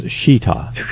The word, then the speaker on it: shechita: S-C-H-E-C-H-I-T-A? – S-H-E-C-H-I-T-A. – But it's pronounced Shita.